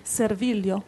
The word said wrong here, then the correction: servigio: No, servigio, sorry.